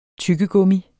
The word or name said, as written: tyggegummi